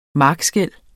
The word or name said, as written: markskel